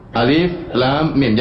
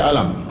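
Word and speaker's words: Alif, lam, mim (الم): Alif, lam, mim. Jangan dibaca alam, keliru. Jangan dibaca alam, ya.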